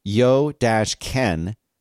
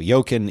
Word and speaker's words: Jochen: And he says, pronounce yo-ken in the first non-German approximation. So Jochen is the correct pronunciation,